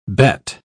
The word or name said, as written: bet